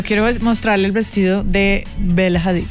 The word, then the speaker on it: Bella Hadid: Bella Hadid. – Bella Hadid, sí.